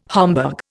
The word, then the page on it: Homburg: Indian